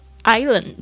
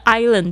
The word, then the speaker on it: island: 好，我梦想的这个假期呢，就是在一个热带的岛屿上，tropical island. 这个 island 这个字不要念成 Island，是 island，那个 S 不发音，Island，注意一下 S 不发音，island。